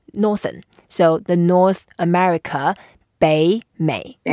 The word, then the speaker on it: 北美: So we say 北美.